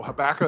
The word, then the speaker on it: Habakkuk: Habakkuk is spelled H-A-B-A-K-K-U-K.